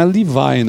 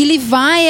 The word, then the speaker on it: Levi: E aí a gente vê que não é Levi, né? Que nem o pessoal fala. – É que Levi é, o que, é a forma como os americanos falam.